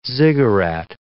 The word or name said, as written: ziggurat